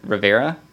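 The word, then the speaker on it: Riviera: Um, and then San Diego, California to Baja and the Mexican R- Rivera. Am I pronouncing that correctly? yeah.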